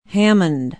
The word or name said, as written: hammond